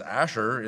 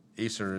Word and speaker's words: Aser: It says that she was of the tribe of Aser, known as Asher in the Old Testament. Aser is the Greek pronunciation of the tribe of Asher.